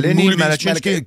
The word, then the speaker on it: Lenny Malachinsky: Udtales det anderledes end det? – Det er Lenny Malachinsky.